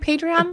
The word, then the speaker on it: Patreon: Or maybe for her Patron, or am I saying it right? Patreon. Patron. I'm thinking of tequila.